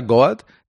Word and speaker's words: GOAT: Es el eh, Goat, se pronuncia Goat del vestuario.